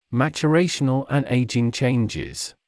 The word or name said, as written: maturational and aging changes